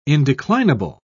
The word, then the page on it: indeclinable: British English